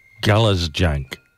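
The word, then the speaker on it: Galešnjak: There is a heart-shaped island in Croatia called, oh gosh, Galazjank. There's a rogue N in the middle of the word.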